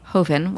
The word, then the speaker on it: Joven: which it's spelled like Joven, all of you people out there, because I which was what I was going to, how I was going to pronounce it until I learned better.